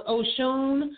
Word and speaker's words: Oshun: You know, I was like, oh my, that's why I put, like, her name, the goddess name is actually pronounced Oshun, but I pronounce mine as Ocean.